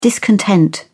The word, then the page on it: discontent: British English